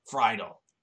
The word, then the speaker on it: Friedle: You, if you're gonna get Kevin Conroy to come back for every Batman game, you're gonna go get Will Wheadle. Wait, is that his last name? Am I saying that wrong? – Oh, right off. – Friedle.